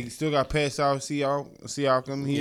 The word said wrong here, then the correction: Pascal: Pascal Siakam.